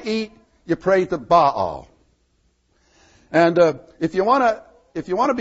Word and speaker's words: Baal: Baal, we sometimes call him Baal, but it's really two syllables.